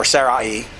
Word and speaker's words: Sarai: Sarai, or Sarai, depending on your pronunciation, becomes Sarah.